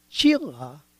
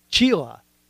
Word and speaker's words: Csilla: Now her name is Chila. That's right, Chila. Okay, now it's spelled C-S-I-L-L-A. That's why I had to pause a little bit and remember how she told me to pronounce it like a cheetah. Chila.